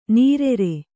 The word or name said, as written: ನಿರಾರ